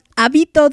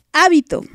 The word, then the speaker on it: Hábito: Tercera canción, Hábito de ti. Hábito, con acento en la A, no hábito.